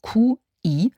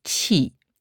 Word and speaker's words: Qi: Im Deutschen geschrieben Q-I ausgesprochen Qi. Vierter Ton, herabfallende Lautung.